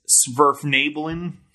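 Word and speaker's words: Svirfneblin: I believe it's Sverfnabelin.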